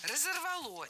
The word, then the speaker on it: разорвало: И, грубо говоря, выйти уже спокойно, открыть дверь, чтобы не разорвало конструкцию. – Разорвало. Неверно. Правильно, разорвало. Ударение на окончании не ставим. В качестве шпаргалки используем начальную форму. Разорвать, разорвало.